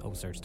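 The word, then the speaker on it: OCEARCH: So there is a website and also an app called OSearch. Now, it's spelt search with a C, but the rest of it is, as you'd expect, OSearch to be.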